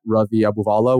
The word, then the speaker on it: Ravi Abuvala: It's all the exact same, just at Ravi Abuvala, which I think Michael will put in the notes for you.